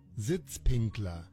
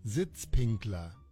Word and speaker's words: Sitzpinkler: Oh, there's a way to pronounce it. Say it again. – Sitzpinkler. – There it is. – It's got the accent and everything. – Sitzpinkler.